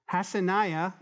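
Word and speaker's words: Hassenaah: Also, the hands of Satsani built the fish gate, and they laid its beams and hung its doors and its bolts and its bars. Hassaniah, I said that wrong first time, Hassaniah built the fish gate.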